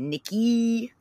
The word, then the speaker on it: Nikkei: So the Japanese stock exchange, I'm going to say, how do you say it? Nikkei. That's how they say it.